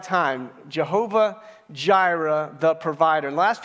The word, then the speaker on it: Jireh: The name of the series is actually a name for God in the scriptures, and the way you say it is Jireh, Jireh.